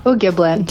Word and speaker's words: O'Gieblyn: O'Gieblyn.